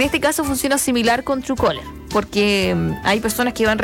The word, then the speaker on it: Truecaller: como Truecaller. Ahí nos pueden preguntar al WhatsApp, oiga, señorita, ¿cuál es la aplicación? Se lo enviamos aquí el nombre. Se escribe Truecaller, pero ese es Truecaller.